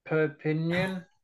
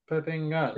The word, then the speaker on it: Perpignan: In 2003, you left Leicester Tigers to join Perpignan. So, how, do you, how do you pronounce it? Perpignan.